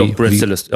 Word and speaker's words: Breadstylist: Schreibt man so, wie man es spricht, ne? – Ja, Breadstylist. Ja. Ne? Auch auf Instagram aktiv. – Quasi wie Brot auf Englisch und Stylist, wie derjenige, der daraus einen besonderen Style entwickelt hat.